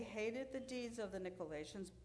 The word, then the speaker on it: Nicolaitans: And I know I say this wrong every time. Nicolaitans. Is that how we say it? Thank you, Nicolaitans.